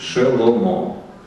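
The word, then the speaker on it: шеломо (שְׁלֹמֹה): Но по нашим правилам, да, мы должны произносить шеломо.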